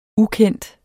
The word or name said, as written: UKENDTES